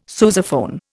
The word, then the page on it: sousaphone: Indian